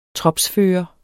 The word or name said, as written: tropsfører